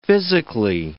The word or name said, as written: physically